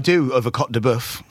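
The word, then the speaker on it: côte de bœuf: One of my favorite pieces of meat is a cote de boeuf. Did I say that right? Cote de boeuf. Yeah, you did.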